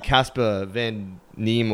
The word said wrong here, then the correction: Casper Van Dien: or however you say his name. He has Van Dien, sorry, Casper Van Dien.